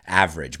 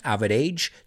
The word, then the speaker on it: average: The New York Subway Pizza Connection basically states that the average pizza slice, average, vamos a pronunciar esto, no es average, sino average, dos sílabas, average.